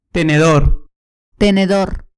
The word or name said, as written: tenedor